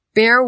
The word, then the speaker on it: bear: When speaking about the animal, I must mention bear rhymes with hair. That bear has a lot of hair. A bear probably doesn't drink a beer. Okay, do you hear the difference? A bear doesn't drink a beer. Beer.